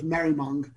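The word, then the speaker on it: Maribyrnong: It's Maribymoung. So the B is silent, so it's Merrimung.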